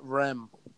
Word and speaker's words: Reims: Friday, I used to work with a French guy, and it's actually pronounced Reims or Rome. It's not. There is no like. It's like Rem, right? – Really? – As he kept saying, it's not Reims, it's Rem.